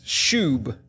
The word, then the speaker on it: shub: Now, the Hebrew word translated turn or repent is shub. S-H-U-B is how we'd spell it.